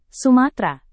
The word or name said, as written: sumatra